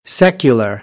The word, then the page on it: secular: American English; ɚ